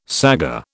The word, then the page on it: saggar: British English